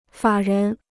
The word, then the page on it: 法人: fǎ rén